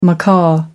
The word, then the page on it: macaw: American English